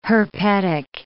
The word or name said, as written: herpetic